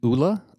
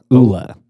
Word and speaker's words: Oola: It's Ula. – Ula. – Yeah, O-O-L-A.